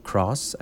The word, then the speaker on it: Krauss: Cross, I think it's Cross maybe. It's spelled like sauerkraut, K-R-A-U, and then S-S, sauerkraut.